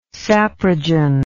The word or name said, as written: saprogen